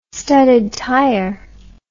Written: studded tire

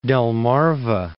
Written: Delmarva Peninsula

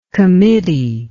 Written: committeeship

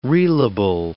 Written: reelable

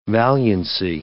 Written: valiancy

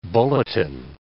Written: bulletin

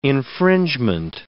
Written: infringement

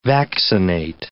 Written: vaccinate

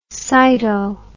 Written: cyto

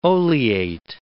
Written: oleate

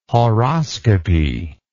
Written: horoscopy

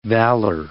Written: valour

